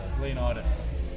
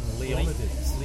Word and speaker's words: Leonidas: King Leo, no, it's King Leonidas. That's how they pronounce it, it's King Leonidas. – Leonidas. You're doing Dan Carlin pronunciation rather than the Hollywood version.